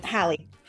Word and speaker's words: Hale: Hallie. – Holly. Hallie. – Like Halle Berry, like Halle Bailey.